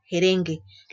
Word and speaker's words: erengue: Lo mismo con la palabra erengue, se pronuncia jerengue.